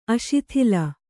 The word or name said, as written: ಅಸ್ಥೂಲ